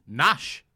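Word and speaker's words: Knash: Called Nash. – With a K. – Which I read. – Wolfpack is back. – So, yeah, the K is silent.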